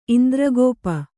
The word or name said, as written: ಇಂದ್ರಗೋಪ